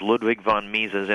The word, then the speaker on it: Ludwig von Mises: My pleasure, Jim, and I'm happy to say you're the first radio host who's ever correctly pronounced Ludwig von Mises Institute, so thank you very much.